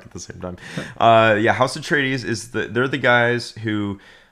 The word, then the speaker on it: Atreides: I want to say Atreides.